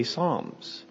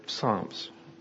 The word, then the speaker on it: Psalms: And for us, we just kind of drop the P. We make it a silent P and we say Psalms. For Koine Greek, which is the Greek that the common person spoke in the time of Jesus, it would have been said with a soft P, not a silent P.